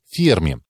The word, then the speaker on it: Ферми: Кстати говоря, в русском языке устоялось произнесение фамилии итальянского физика с ударением на последний слог, как у французов, но правильное произношение с ударением на другой слог – ферме. Итальянский язык самый близкий к латинскому и унаследовал правило ударения на предпоследний слог.